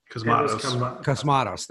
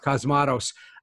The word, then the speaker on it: Cosmatos: Cosmatos, is that it? – Cosmatos, thank you. Cosmatos said,